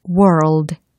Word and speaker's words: world: world, world. 와우. 난이도가 좀 있죠. 특히 R과 L이라는 철자가 붙어 있어서 r o l l r o l l 약간 용수철처럼 튀어오르는 그런 발음이에요.